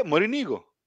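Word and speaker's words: Morínigo: E falando do jogo de hoje, o como que se pronuncia? É Morigno mesmo que pronuncia, ou tem, ou temos alguma variação aí no sobrenome do nosso técnico? Cara, eu não consigo entender por que o problema de vocês para pronunciar, porque aqui é. – Como é, fala? Claro, é Morinigo.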